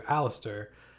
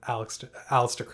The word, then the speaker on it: Aleister: He abandoned Edward and changed Alexander to Aleister specifically because he heard that the most pleasing format for a name was the rhythm pattern that Aleister Crowley gives you. That like first syllable stress, three syllables, and then first syllable stress, two syllables.